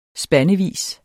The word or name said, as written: spandevis